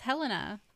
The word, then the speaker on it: Helena: You can do it every single time now that I say you just said it wrong 4 times in a row. – It's not wrong. – Yes, it is. You looked it up. – Yeah, it's Helena.